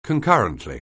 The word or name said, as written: concurrently